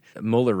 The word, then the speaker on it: Mueller: and this is the redacted Mueller report. We'll just call him Mueller. We know that the German pronunciation is different, but that's how he goes.